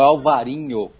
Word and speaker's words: Alvarinho: Em Portugal é Alvarinho com V e NH, e na Espanha é Albarinho com B de bola e o N com tio em cima, né, como se escreve em espanhol.